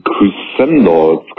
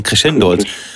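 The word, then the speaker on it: Crescendolls: Ähm, da ist das Album, wie spricht man es denn aus? Die Crescendals. Crescendals? – Achso, ja, äh, Crescendolz. Ich, ich glaub, würde ich sagen Crescendolz. Weil es kommt, glaube ich, vom Crescendo.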